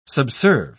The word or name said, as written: subserve